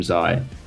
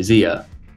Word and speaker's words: Zai: And then we've even had, like, for Zai, we've had people say Zia, which is not the spelling, including family members who I won't name.